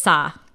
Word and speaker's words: Sa: Yeah, it's Sa.